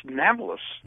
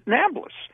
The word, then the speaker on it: Nablus: And in the same way, when they try to pronounce Naples, it came out as Nablus. Mm-hmm. And that's why they pronounce it Nablus.